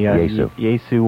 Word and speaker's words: Yaesu: Yaesu. – Y-A-E-S-U.